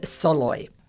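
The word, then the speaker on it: Szalay: David Soloy, but that's not phonetically how it's spelled. So it's S-Z-A-L-A-Y.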